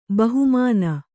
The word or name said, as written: ಬಹುಮಾನ